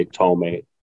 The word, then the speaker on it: Tohme: Even when I introduced myself, I kind of used an Americanized version of it because some people find it hard to pronounce Tohme, which is like phonetically, it's hard for those who do not speak certain languages. In my case, it's an Arabic last name. But basically, I just say Tohme.